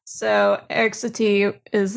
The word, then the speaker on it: Satie: Your topic is Erik Satie's performance indications. Do you know if I pronounced that right? – You didn't. – Okay. – It's Erik Satie.